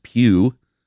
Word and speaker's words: Pugh: Alexandria Runyon says, Florence Pugh. Is that how you pronounce her name? – That is.